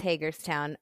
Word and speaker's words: Hagerstown: Is that how you pronounce it, by the way? Hagerstown? – Yes, it's Hagerstown. Okay.